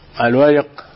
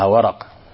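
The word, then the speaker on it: الورِق: قوله الورق، الورق بكسر الراء هي الفضة، بعض الناس قد ينطقها ورق وهذا خطأ، الفضة يقال لها ورق بكسر الراء.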